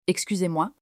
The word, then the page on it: Excusez-moi: French